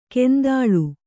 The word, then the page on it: ಕೆಂದಾಳು: kendāḷu